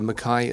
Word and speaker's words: Mackay: Well, it's of course it's well R O R Y for the Rory, but uh, Mackay is spelled M A C K A Y.